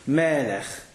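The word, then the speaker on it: Melech (מלך): Melech. Stress on the mem.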